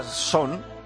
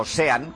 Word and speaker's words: Sean: Curioso, porque hay quien a ambos les llama Son... O sean, como se escribe, o sea, sean Penn, sean Connery. – Uh-huh. – Pero mucha gente dice, sin Connery, sí. Son Penn. O sí. Son Connery, sin Penn.